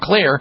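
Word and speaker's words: Xlear: Well, Clear, by the way, is spelled with an X, X-L-E-A-R. The X stands for xylitol.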